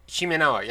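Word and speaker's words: shimenawa: Like, even in some cases where you end up with sacred trees and everything, you have the Shimanawa rope and everything tying around it. I think that's how you pronounce that. – Right. Shimanawa, yeah.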